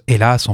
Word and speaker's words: Alas: On va commencer par le groupe Alas, qui se prononce Hélas en fait, hein, qui est un groupe, tiens, suédois, je crois.